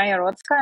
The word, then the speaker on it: Iarotska: So yeah, I'm Anna Iarotska.